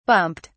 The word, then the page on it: bumped: British English